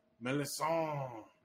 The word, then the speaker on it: Melisandre: Melisandre. – Melisandre is how they say it.